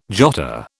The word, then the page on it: jotter: British English